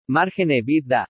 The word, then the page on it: MARGEN EBITDA: spanish